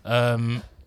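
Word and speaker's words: Joorabchian: Jirabchin. – Yeah, I can never pronounce. Is that you pronounce his name right? Yeah. Jirabchin.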